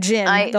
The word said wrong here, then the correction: Jin: the whole time. – When I read it, I was like, "Oh, it's spelled J-I-N." But the narrator, my ever lovely Grover, says it like Gene, G-E-N-E.